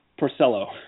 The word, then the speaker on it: Porcello: His name is pronounced Porcello.